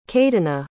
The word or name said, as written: cadyna